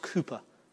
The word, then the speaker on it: Cowper: It's spelled Cowper, but it's pronounced Cooper.